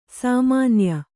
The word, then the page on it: ಸಾಮಾನ್ಯೆ: sāmānye